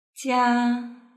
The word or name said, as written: jia